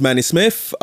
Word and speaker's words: Manny Smith: My name is Manny Smith. – How do you spell that? – M-A-N-N-Y, Smith.